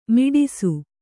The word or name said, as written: ಮಡಿಸು